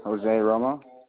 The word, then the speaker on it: Jose Romo: Jose Romo.